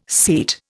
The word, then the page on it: seat: Indian